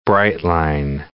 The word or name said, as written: bright-line